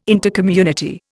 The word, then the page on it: Intercommunity: Indian